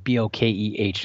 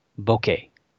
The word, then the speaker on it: bokeh: It's spelled B O K E H. Not a lot of people argue about how to say that, but uh, popular way that I've heard is bokeh or bokeh.